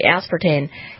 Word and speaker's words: aspartame: And that because Diet Dr. Pepper had aspartame, am I saying that right? – Aspartame. – Aspartame. I knew that was wrong.